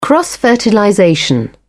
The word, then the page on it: cross fertilization: British English